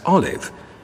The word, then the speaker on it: Olaf: Some say our curious pronunciation of Olaf as Olive comes from the Cockneys who worked the nearby docks. Others hear in it a closer echo of the Norwegian.